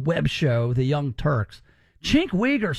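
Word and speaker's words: Cenk Uygur: Is that his name, Chink Wigger? – Yeah, I think that's how you say it.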